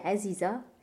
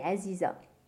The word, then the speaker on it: Aziza: Comme moi, je m'appelle Aziza, mais en français, on prononce Aziza. – Mm-hmm. Et donc, c'est donc Aziza et Aziza. – C'est Aziza.